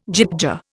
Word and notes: gyttja: Indian

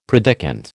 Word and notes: predikant: American English

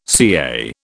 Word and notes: CA: British English

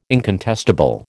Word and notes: incontestable: American English

